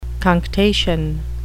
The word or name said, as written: cunctation